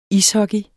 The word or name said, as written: ishockey